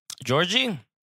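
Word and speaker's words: Djordje: Georgie? – First of all, love a dude with 2 silent D's in his name.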